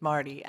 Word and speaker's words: Marty: I mean, I have a great name because my name's Marty, and party rhymes with Marty.